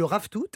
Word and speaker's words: ravitoto: Ça se dit le raftout.